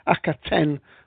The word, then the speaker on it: Akhaten: I should have said that the, the episode we are talking about, because uh, we're having some argument about the pronunciation. Apparently, it's pronounced differently twice during the program, but Akhaten was the way that the doctor said it there.